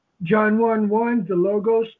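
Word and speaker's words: logos (λόγος): The word in the Greek language, they pronounced it logos.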